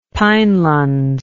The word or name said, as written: pinelands